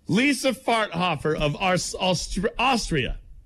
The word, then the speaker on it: Farthofer: Lisa Farthofer, that's her name. – Hold on a sec. – Or is it Farthother? – You go from rowing into Farthofer. How do you spell it? – Farthofer. That's her name. Lisa Farthofer. – Spell it for me. – F-A-R-T-H-O-F-E-R.